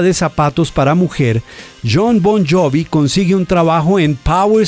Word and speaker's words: Bon Jovi: Su apellido paterno tenía una pronunciación más o menos italiana, Bon Jovi.